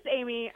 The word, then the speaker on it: Amie: Yeah, no, it's Amie. I've gotten Annie a lot. The red hair doesn't help. And then Arnie sometimes too.